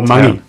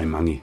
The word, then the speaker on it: Mangi: Which must be the or Mangi. If you're a local, you just say Mangi.